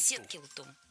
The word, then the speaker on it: Сеткилту: Ну вот ударение в книжке прям стоит на второй слог. Я думаю, что я правильно произношу. В одном селении... – Это звали его так, Сеткилту? – Сеткилту, да.